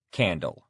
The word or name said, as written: candle